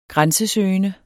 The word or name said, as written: grænsesøgende